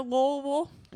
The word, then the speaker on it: Louisville: Should we turn our attention to Louisville? – Yeah, you can't pronounce any syllables before the vill. – Swallow all of the letters.